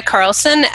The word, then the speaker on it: Karlsson: It's Maya Carlson. My name is spelled weird, but there aren't very many of us.